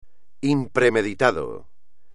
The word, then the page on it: impremeditado: espagnol